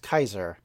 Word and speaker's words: Caesar: So thus, in Old Latin or Latin of the classical period, you would say what we now say as Caesar would be pronounced Kaiser. Because, of course, they had a hard C, not a soft C.